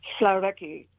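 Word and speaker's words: hvalreki: And you should know the word, and I'm still not perfect on my pronunciation here, okay, but Shalaki, which is Shalaki? – A whale stranding. – Oh, wow. – It's written with an HV. There's an L in there, but Shalaki means, it's sort of a benediction, right?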